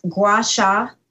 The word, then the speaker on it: Gua Sha: So is it pronounced Gua Sha? – Yes.